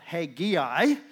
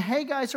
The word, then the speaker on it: Haggai: Some of you get a little extra syllable in there. That's the way I was taught. Haggai. We get three syllables in there, but often it's just Haggai. And Haggai's a prophet who is going to prophesy to them after they've come back from exile.